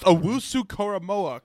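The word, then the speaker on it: Owusu-Koramoah: It's pronounced Owusu Koromoa.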